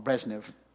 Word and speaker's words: Brezhnev: Excuse me, I always want to say that wrong, Brezhnev.